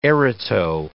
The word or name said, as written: Erato